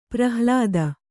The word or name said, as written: ಪ್ರಹ್ಲಾದ